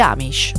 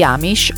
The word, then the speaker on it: Amish: Amish. Quando si parla di Amish o Amish in base alla pronuncia americana,